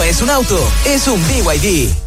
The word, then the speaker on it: BYD: Nada que ver, se escribe BYD, pero se dice BYD, la marca número uno de electromovilidad en Chile y el mundo.